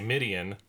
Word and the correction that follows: median: You mean median? – Or median, sorry. Median. I saw the word, I just said it wrong.